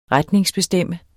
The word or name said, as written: retningsbestemt